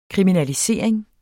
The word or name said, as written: kriminalisering